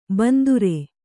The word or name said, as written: ಬಿಂದಾರಿ